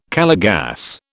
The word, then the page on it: Calor gas: British English